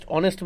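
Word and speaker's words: honest: हॉनेस्ट में क्या silent होता है? – ऑनेस्ट में क्या साइलेंट होता है? – हेच.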